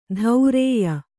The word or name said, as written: ಧೌರೇಯ